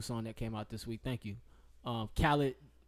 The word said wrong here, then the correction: Khalid: Am I saying his name right? Khaled? – He's Khalid.